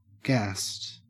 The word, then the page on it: gassed: Australian English